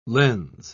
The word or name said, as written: lens